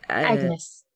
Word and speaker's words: Agnes: I might pronounce it wrong. – Agnes.